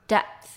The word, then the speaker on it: depth: 就是 P depth depth 它跟 death 死亡这个字呢发音非常的像只差那个很微小很微小的 P 所以是 depth 跟 death 那最后我们要来看的是 filter Filter 呢是滤器啊或者是滤镜的意思它可以当名词也可以当动词。